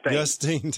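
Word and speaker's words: Justin: Justine, Texas, yes. I always say it wrong. I'm sorry. – It's like Augustine, but without the A-U.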